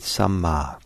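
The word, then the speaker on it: sammā: That's eight times that word right is repeated, samma, and S-A-M-M long A. And I'll talk more about this, but the opposite of it is miccha, M-I-C-H-A, and it's wrong view, wrong intention, wrong, each of them, action.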